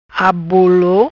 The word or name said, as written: Abolo